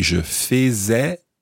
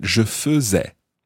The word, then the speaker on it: faisais: So we don't say je faisais, but je faisais, I was doing. Je faisais, F-A-I-S-A-I-S.